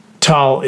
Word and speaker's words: Tolle: Many say Tolle, but Tolle is the correct pronunciation.